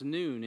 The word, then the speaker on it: Nun: This is the letter Nun, but actually it's pronounced Noon in Hebrew. So Noon, even though it's spelled N-U-N.